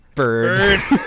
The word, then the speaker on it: BrdU: BRDU, which is pronounced bird.